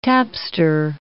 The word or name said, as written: tapster